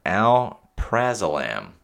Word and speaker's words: alprazolam: Alprazolam.